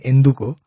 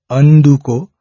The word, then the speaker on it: Anduko: So one must be very careful of pronunciation also here. It's Anduko.